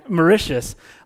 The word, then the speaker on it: Mauritius: And the place is Mauritius, Mauritius, okay, Mauritius. Apparently that's how you say it. Mauritius is how you actually say that.